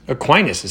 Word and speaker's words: Aquinas: Aquinas is how you say it.